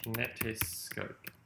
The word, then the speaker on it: kinetoscope: Kinetoscope. – It's spelt K-I-N-E-T-O-S-C-O-P-E, kinetoscope. – Oh, I had an I.